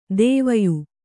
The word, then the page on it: ದುವಯಿ: kannada